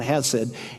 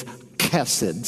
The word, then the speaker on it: chesed: People mispronounce it all the time. It's not hesed. It's chesed. Say that, chesed.